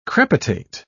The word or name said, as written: crepitate